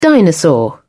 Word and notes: dinosaur: British English

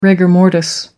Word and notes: rigor mortis: American English

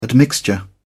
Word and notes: admixture: British English

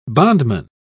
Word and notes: bondman: British English

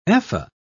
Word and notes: ephah: British English

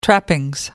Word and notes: trappings: American English

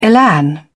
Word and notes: elan: British English

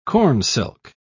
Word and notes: cornsilk: British English

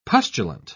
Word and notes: pustulant: British English

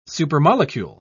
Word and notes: supermolecule: British English

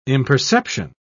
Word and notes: imperception: British English

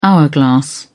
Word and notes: hourglass: British English

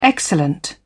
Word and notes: excellent: British English

